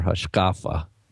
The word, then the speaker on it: hashkafa: When he says we need to include Oral Torah as part of our Hashkafa, which is our good Ashkenazi pronunciation, is he extending the canon? – Yes.